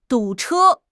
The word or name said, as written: du3 che1